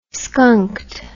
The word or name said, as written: skunked